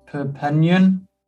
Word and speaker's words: Perpignan: In 2011, you joined French side Perpignan. – Yeah. – How's it pronounced? – Perpignan. – Yeah, so it's a mouthful, that one. Perpignan, some people pronounce it Perpignan, but I know what you mean, don't worry.